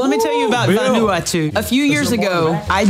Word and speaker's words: Vanuatu: Vanuatu. – Vanuatu.